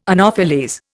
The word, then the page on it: anopheles: Indian